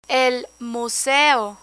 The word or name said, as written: el museo